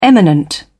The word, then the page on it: eminent: British English